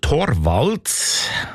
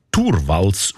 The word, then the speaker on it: Torvalds: Der Linus Torvalds, eigentlich Turvalds ausgesprochen, damals Informatikstudent Helsinki, er hat die wertvollste Software der Welt namens Linux einfach verschenkt.